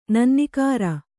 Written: ನನ್ನಿಕಾರ

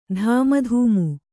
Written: ದೂಮುಧಾಮು

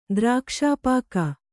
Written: ದ್ರಾಕ್ಷಾಪಾಕ

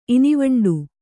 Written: ಇನಿವಂಡೀ

